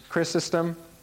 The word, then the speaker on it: Chrysostom: Chrysostom.